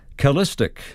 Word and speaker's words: chalastic: And this morning's word is calistic. I'll spell it C-H-A-L-A-S-T-I-C. Calistic.